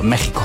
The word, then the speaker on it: México: El doctor Carlos de las Heras nos ha dicho que se escribe con X, pero se pronuncia con J, por ejemplo, México.